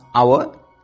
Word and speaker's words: hour: H-O-U-R. Here you see the first letter is completely silent. So we pronounce our. What is the correct suffix? Here we have A-B-L-E. L-E-S-S, L-Y, M-E-N-T, ment, N-E-S-S, ness.